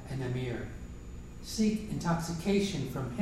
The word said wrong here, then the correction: Amr: First, the pronunciation is Zayd and Amr.